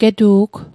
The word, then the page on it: getu'g: Mi'gmaq